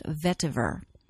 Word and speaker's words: vetiver: I have seen it spelled with a T at the end, but generally it does not have the T, but it's always pronounced vetiver.